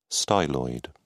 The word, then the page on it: styloid: British English